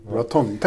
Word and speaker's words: Raton: Raton. Tänk på batong. – Ja. Batong. Just det, Boca batong. Mm.